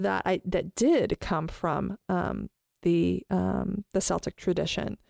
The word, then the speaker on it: Celtic: So I need to get, it's a hard C, by the way. – Celtic.